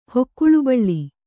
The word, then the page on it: ಹೊಕ್ಕುಳುಬಳ್ಳಿ: hokkuḷu baḷḷi